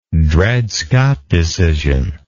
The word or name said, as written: Dred Scott decision